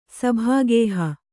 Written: ಸಭಾಗೇಹ

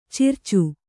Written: ಚಿರಚ

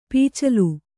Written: ಪಚ್ಚೋಲೆ

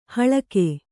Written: ಹಾಲಿಗೆ

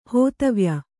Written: ಹೋತವ್ಯ

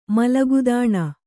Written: ಮಲಗುದಾಣ

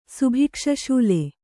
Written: ಸುಭಿಕ್ಷಶೂಲೆ

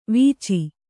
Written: ವೀಚಿ

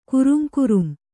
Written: ಕುರುಂಕುರುಂ

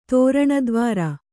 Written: ತೋರಣದ್ವಾರ

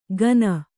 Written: ಗನ

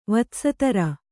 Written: ವತ್ಸತರ

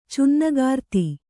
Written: ಛಿನ್ನಾಗ್ರತೆ

